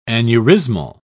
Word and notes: aneurismal: British English

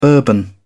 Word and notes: bourbon: British English

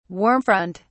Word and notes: warm front: British English

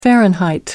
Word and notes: fahrenheit: American English

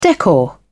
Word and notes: decor: British English

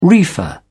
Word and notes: reefer: British English

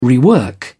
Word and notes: rework: British English

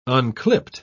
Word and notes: unclipped: British English

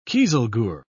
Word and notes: kieselguhr: British English

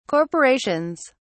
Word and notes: corporations: British English

